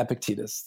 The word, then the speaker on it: Epictetus: I don't know if I'm pronouncing it correctly, but Epictetus? – Yeah, Epictetus.